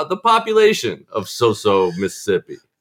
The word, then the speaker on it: Soso: I was actually born and spent my first 18 years in a town called Soso, Mississippi. – And that's spelled S-O-S-O, if I'm not mistaken. – That is correct. – S-O-S-O. Oh, Soso.